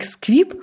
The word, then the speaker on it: SQIP: Сквиб э, Тут даже, кстати, приписано смешно, что оно произносится как сквип, как в Гарри Поттере не магический, значит, чувак, произошедший из магической семьи.